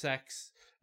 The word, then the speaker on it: CeX: I'm not sure if it's in the USA, and it's spelled C-E-X, and it's pronounced Sex.